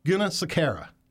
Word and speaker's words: Gunasekara: I want to present to our Power Hour audience, Mandy Gunasekara. Did I say it right? No, I didn't. Did I? – It was really close. Gunasekara.